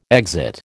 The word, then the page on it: exit: American English